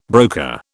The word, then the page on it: broker: British English